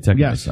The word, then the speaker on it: Technos: Did I say that right? Technos.